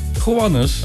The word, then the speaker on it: Gowanus: Gowanus. – Oh, Gowanus. Ik dacht, daar staat Jannes of zo, maar dat is Gowanus.